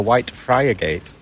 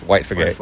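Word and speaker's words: Whitefriargate: When you see it written down and visitors come and pronounce it correctly, they say we're looking for White Friar Gate. – That's right, yeah. But in Hull, it's White Frigate.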